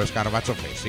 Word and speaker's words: Escarbacho Fest: Esta vez se aproxima mucho a como lo digo yo, Escarbacho Fest, sí.